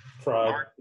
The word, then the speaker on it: Fried: Like, Fried, or however you say his name, is pretty good. – Fried. It's like a fried egg.